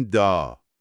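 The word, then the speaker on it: dağ: اینجا گ یا غ تلفظ نمیشه. فقط آ رو میکشیم و میگیم دا.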